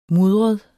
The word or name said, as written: mudret